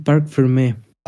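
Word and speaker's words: parc fermé: Sí, sí, sí, está bien, está bien, Park Fermé.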